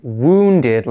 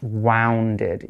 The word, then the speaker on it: wounded: And remember, when you're looking at this one, you need to remember that the pronunciation of wounded is wounded, like oo, like moon, and not wounded, even though it's got a u.